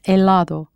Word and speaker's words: helado: The Spanish word for ice cream is helado, spelled H E L A D O, with that always silent H.